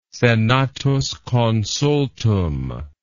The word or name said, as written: senatus consultum